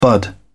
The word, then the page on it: bud: British English